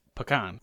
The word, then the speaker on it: pecan: Not even pecan. It's pecan. – Oh, pecan. – It's almost like one syllable.